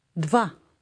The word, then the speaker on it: Два: It starts with a DV combination, as we have in the combination of the English words bud, vase. Listen and repeat. – Два.